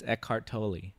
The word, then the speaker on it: Eckhart Tolle: Am I pronouncing his name right? – It's Eckhart Tolle. – Tolle. – I don't really know if that's right. – Yeah. No, that's right.